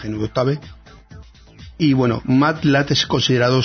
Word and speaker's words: Octave: Octave, tal cual está escrito, no sé cómo se pronuncia. Octave. – Uh-huh. – O-C-T-A-V-E. ¿Cómo se pronuncia eso? – Pues Octave. – Octave, ¿no? Bueno, pues Octave o GNU Octave es un programa libre para realizar cálculos numéricos.